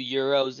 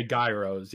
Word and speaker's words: gyro: By gyro, are you guys referring to gyros as in like gyros? – Yeah. Yes. Yeah. – Okay. – You pronounce it gyro. But in America, we would say gyros, yeah.